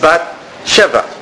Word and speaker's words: Bat Sheva: The word Shua, if you pronounce the Vav as a Vav and not as a vowel, it's Bat Sheva. That's Bat Sheva.